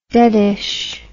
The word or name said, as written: deadish